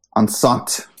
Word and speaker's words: enceinte: One is from Lucy's Enciente. Is that how you say it? – Enceinte. It's French.